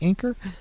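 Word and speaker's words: Anker: Is that how you say it? Anchor? – Mm-hmm.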